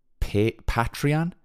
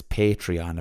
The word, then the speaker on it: Patreon: I've been pronouncing that wrong since the start of this podcast. I've been saying Patreon, but it's Patreon apparently.